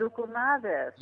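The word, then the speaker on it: loukoumades: Lucamati's. Is that how you pronounce it? – Lucamades. – Lucamades.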